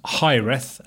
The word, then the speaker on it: Hiraeth: And this week's is, I think it must be pronounced Hireth, H-I-R-A-E-T-H, is a Welsh word,